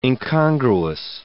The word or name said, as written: incongruous